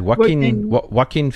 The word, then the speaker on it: Joaquin: The J is silent in what? How do you then? How do you say it? Joaquin Phoenix.